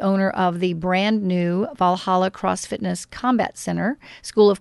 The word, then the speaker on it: Valhalla: And I love how you say the name right, Valhalla. That's really good. A lot of people have a hard time saying that.